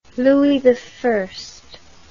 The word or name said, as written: louis i